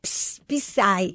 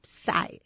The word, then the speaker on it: Psy: Yo decía Psy, y entonces Teudy me dijo, no, se dice Psy, la P no se dice.